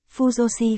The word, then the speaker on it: Fujoshi: Cách gọi này hài hước trào lộng vì trong tiếng Nhật phát âm giống từ phụ nữ tử, phu nu di nghĩa là phụ nữ, Fujoshi Fujoshi.